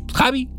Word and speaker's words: Xavi: el Javi, así más No, no Xavi, o sea, se escribe como el, como el director de Barcelona que ya no tardan en correr, pero pues Xavi y la Diabla.